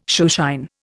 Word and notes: shoeshine: Indian

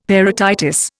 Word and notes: parotitis: Indian

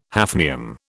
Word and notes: hafnium: British English

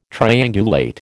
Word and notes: triangulate: American English

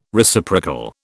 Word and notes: Reciprocal: British English

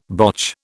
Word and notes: botch: British English

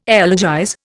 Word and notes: elegize: Indian

